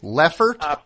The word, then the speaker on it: Lefferts: Leffert? – Prospect and then Leopards, L-E-F-F. E-R-T-S mm-hmm.